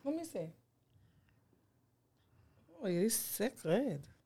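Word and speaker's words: Cécred: It's spelled C-E-C-R-E-D. There's a little accent over the E. I think it's sacred. Sacred? – Sacred.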